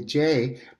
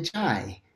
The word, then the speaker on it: Jai: And also my Indian friends call me Jai, but all the Westerners that I know, which is hundreds of thousands of people, call me Jai.